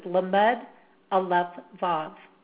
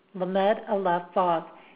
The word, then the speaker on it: Lamed Alef Vav: And again, the common name is Banishing the Remnants of Evil, and the way the name is actually pronounced is Lamed Alef Vav. Lamed Alef Vav.